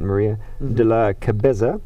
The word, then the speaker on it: Maria de la Cabeza: Saint Isidore and his wife, Saint Maria de la Cabeza. Did I pronounce that right? Yes, that's right.